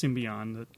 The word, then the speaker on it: Symbion: Sektars, Warriors of Symbion, and it is pronounced Symbion, correct? – I believe so, yeah. – Okay, that's how I would say it, was a line of action figures released by Coleco in 1985.